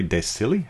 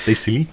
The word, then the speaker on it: Silly: This one, and one from Brasserie de Silly. How do you say it? S-I-L-L-Y, Mr. French. – De Silly. – There you go. De Silly.